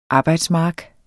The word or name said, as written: arbejdsmark